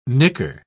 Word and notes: nicker: British English

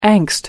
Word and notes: angst: American English

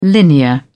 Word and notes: linear: British English

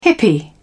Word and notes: hippie: British English

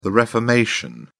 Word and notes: reformation: British English

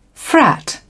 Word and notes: frat: British English

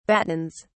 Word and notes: battens: British English

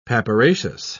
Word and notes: papyraceous: British English

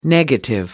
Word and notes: negative: American English

